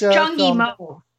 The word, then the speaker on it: Zhang Yimou: So the movie of the week was chosen as Hero, directed by Zhang Yimou. It was a 2002 Chinese wuxia. Am I pronouncing that right? – Yeah, but wuxia, it's Zhang Yimou.